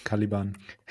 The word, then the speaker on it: Caliban: Können wir ein für alle mal klären, ob diese Band Caliban oder Caliban ausgesprochen Caliban. Werden will? – Caliban. – Als Deutscher wär's Caliban, ja.